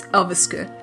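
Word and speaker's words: Elverskud: And thank you to Ricky Hansen, by the way, for telling me how to pronounce that, because I would have pronounced the D at the end. And I think I did mistakenly do that. So I do apologize. So it's Elveske.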